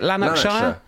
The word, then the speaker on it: Lanarkshire: Hi guys, I have a small business based in Lanarkshire, Scotland. How do you say it? Is it Lanarkshire? – Lanarkshire.